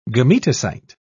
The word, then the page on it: gametocyte: British English